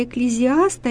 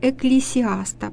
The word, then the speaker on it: Экклесиаста: Еще одна книга очень интересная, которую читали наши предки, которая была своеобразной книгой наставления, это книга «Экклезиаста» или «Экклесиаста», по-разному произносят это слово.